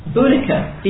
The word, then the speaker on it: Boudica: Boudica. Boudicca. Boudica. – Or Boeticea. – Yeah, lots of pronunciations.